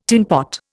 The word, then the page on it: tinpot: Indian